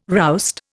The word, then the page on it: roust: Indian